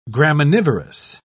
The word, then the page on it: graminivorous: British English